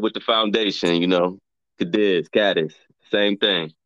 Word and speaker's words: Cadiz: No, actually, it's really, I pronounce it Cadiz, but it's really pronounced Cadiz.